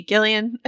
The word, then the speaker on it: Gillian: You know, I was reading Yep, hard G. Gillian Flynn gave a great interview to Megan Abbott in Vanity Fair.